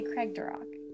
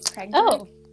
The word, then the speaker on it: Craigdarroch: I really want to say Craig Duroc, but I'm pretty sure it's... The pronunciation thing says Craig and then Derek, like, the name Derek. So, like, Craig Duroc Castle? – Okay.